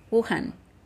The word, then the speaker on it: Wuhan: The Wu, how do you pronounce it, darling? Wuhan? Wuhan. Wuhan. Is that better? – Yeah.